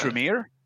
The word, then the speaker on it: Tremere: Tremere. Is that how you pronounce it? Tremere? – Oh, the Camere? Oh, the Tremere? – Yeah, that's right.